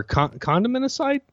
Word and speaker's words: condimenticide: Or condimenticide? I don't. – Ooh, I like condiment. Condimenticide. You have to pronounce the T, otherwise it sounds like, you know, we're condom side or whatever. Condimenticide, you know.